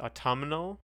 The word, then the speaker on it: autumnal: I like the word, and the reason I like the word is because like you really get to use the the n in autumn. Yeah. Because you know I usually don't pronounce it, but autumnal, you do.